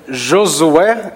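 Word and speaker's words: Josué: Josue is the... if you let me say it, the white version of it, okay? The Josue is the right pronunciation in Portuguese.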